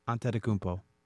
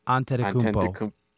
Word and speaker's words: Antetokounmpo: How do you say Giannis' last name? – Antetokounmpo. – Antetokounmpo. – Greek, so it's that first accent that throws Antetokounmpo.